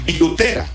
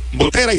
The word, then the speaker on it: Butera: Ellos lo pronuncian Butera. En este caso, el manager de los Nacionales es fonéticamente como se escribe Butera, y su nombre es Blake.